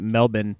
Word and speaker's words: Melbourne: They also won the Best Newcomer Award after a show at the Melbourne, I think the Aussies pronounce it Melbourne,